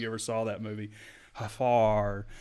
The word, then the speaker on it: Geffrard: Is that how you say it? Jaffard? – Jaffard, yeah. – Jaffard. Jaffard. Oh, there's a sneaky R in there. G-E-F-F-R-A-R-D.